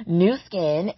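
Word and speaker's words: Nu Skin: New Skin, and that's pronounced, spelled N-U Skin.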